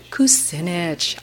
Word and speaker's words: Kucinich: I don't totally know how to say his name, but Kuchinek? What is it? Kucinich.